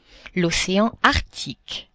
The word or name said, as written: ocean Arctique